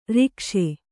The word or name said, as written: ಋಕ್ಷ